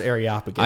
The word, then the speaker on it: Areopagus: It's pronounced Areopagus.